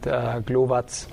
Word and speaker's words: Glowacz: So I pronounce it Glowacz.